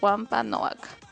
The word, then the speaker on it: Wampanoag: disculpen si lo digo mal, pero es Wampanoag, ayudaron a los peregrinos dándoles semillas y enseñándoles a pescar.